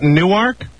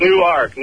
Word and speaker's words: Newark: And do they pronounce it Newark? – Is it Newark? Newark. – So it's not like Newark, New Jersey. It's Newark, Delaware.